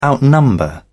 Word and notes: outnumber: British English

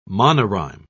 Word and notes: monorhyme: British English

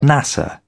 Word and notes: nasa: British English

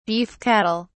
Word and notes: beef cattle: British English

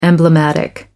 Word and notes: emblematic: American English

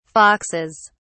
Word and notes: foxes: British English

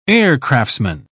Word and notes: aircraftsman: British English

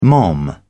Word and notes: mom: British English

